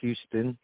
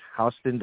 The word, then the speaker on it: Houston: It's spelled like Houston, Texas, but it's actually pronounced Houston, Delaware. There is not one street light in this town.